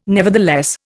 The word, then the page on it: nevertheless: Indian